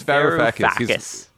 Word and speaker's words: Varoufakis: It's Varoufakis.